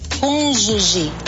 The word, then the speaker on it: cônjuge: Você diz meu cônjuge, lembre-se, o G antes de E e I em português tem som de J. Portanto, nada de cônjuge. A pronúncia correta é cônjuge.